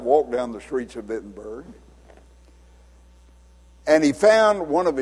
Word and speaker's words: Wittenberg: Now, if you saw that written down in uh, English, we would probably call it Wittenberg because it's spelled with a W, W I T T E N B E R G. But in Germany, they pronounce a W as though it were a V, and so the real name of it is Wittenberg.